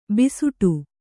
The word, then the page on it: ಭಿಸ್ಸಟೆ: bhissāṭe